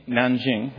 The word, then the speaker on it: Nanjing: Nanjing.